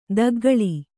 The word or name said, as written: ದೊಗ್ಗಳೆ